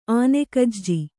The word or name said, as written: ಆನೆಗಜ್ಜಿ